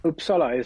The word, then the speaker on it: Uppsala: Tell us where is it Uppsala? Is that the way you pronounce it, or Uppsala? – Uppsala is okay, yes.